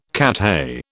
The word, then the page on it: Cathay: British English